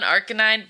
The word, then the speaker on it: ArKainine: Yeah, so ArKainine is ArKainine. It's the Pokemon ArKainine, but instead of C-A, it's my name, Kai.